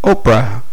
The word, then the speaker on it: Oprah: Oprah. Can you spell that, please? I did. O-P-R-A-H. And the H is silent, I explained.